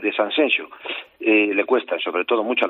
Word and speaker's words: Sanxenxo: Tengo un amigo, precisamente, Mairena del Aljarafe, policía, que para decir Sanxenxo me empezaba diciendo Sanxenxo, Sanxenxo. Y tienes que, bueno, corregirle. No, Eduardo, que no, que es Sanxenxo.